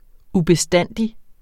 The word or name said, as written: UBESTANDIGT